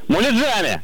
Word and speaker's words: муляжами: Ударение в данном слове всегда ставится только на окончании. Потому что правильно говорить, конечно же, еще раз как? – Муляжами.